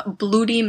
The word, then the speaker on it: Bluidy: He was given the name Bloody Mackenzie, which I assume is... It's like B-L-U-I-D-Y, so I think it's just spelled like Bloody. Bloody Mackenzie.